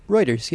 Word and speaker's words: Reuters: But some of our friends did see, because it was Reuters. Am I even pronouncing that right? – Reuters, yeah.